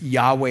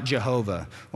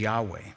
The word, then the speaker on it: Yahweh: Old commentaries that you read, they'll spell it out Jehovah. Well, there's no J in Hebrew, so we know that that's not the correct way to pronounce it. So all the modern commentaries, everybody's pronouncing it as Yahweh.